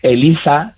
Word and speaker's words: ELIZA: ELIZA, y además está bien pronunciado con Z, aunque yo viva en Cádiz, no es que sea ELIZA y yo le llame ELIZA, que ya me lo han dicho por ahí, no, es ELIZA con Z.